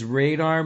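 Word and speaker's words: Reidar: His name is Radar Magnusson. It's R-E-I-D-A-R.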